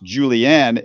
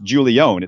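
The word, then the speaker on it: Julién: because I read in an article that his name was not pronounced Julianne, it was pronounced Julione. It's spelled J-U-L-I-E.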